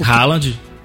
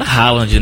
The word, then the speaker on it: Haaland: O Haaland.